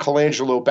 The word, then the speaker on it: Colangelo: His name is Matt Colangelo, is I think how I pronounce his last name. It's C-O-L-A-N-G E-L-O, Colangelo.